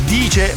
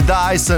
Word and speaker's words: Dice: Allora, gli eventi sono totalmente gratuiti, bisogna soltanto iscriversi sull'app Dice, che si scrive Dice ma si legge Dice, non dite Dice perché poi vi prendono in giro i vostri nipoti se ne doveste avere.